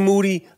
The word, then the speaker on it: Moody: Moody, last name rhymes with duty, at Irving Plaza.